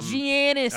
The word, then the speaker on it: Giannis: Yeah, the Milwaukee series is interesting because Giannis Antetokounmpo, Giannis.